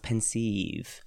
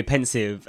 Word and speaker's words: Pensieve: Which movie Dumbledore pronounced pensieve. – Pensieve. – Which kind of makes more sense because it sounds more like sieve. But no, we say that anyway, pensive.